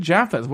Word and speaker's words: Jaffa: How do you say it? – Jaffa. – Which is what I just said, Jaffa. Why do they say it's so weird here in America? – No, you're not saying it right. Try again. – Jaffa? – No, oh God, you fucking Americans. Try it one more time. – Jaffa. – Oh, Lori, I got it. Jaffa? – That's better.